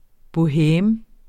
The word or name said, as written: boheme